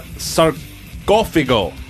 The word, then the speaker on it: Sarcófago: I got Sarcófago.